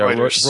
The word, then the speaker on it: Reuters: And they mention, what, Reuters? That's how you pronounce it, right? – Yep, yeah, Reuters.